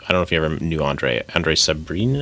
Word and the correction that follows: Sanabria: how do you say his last name? – Sanabria, but he was from Port Vancouver.